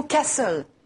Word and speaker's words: castle: Par exemple, le mot « castle ». En anglais, château. – Castle. – On place l'accent tonique sur le début du mot castle.